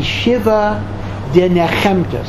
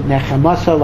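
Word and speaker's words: Shiva D'Nechemta: We read 7 Haftorahs that are connected to comfort and redemption and Geula, and these are called the Shiva Denechemta. Some pronounce it Nechemasa, like in Kaddish. Others say Shiva Denechemta.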